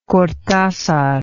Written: cortazar

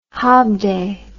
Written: hobday

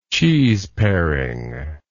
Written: cheeseparer

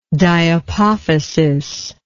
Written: diapophysial

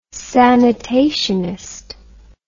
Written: sanitationist

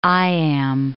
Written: iamb

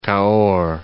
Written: Cahors

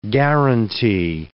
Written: guaranty

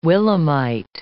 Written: willemite

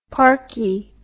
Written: parky

